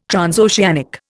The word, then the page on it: transoceanic: Indian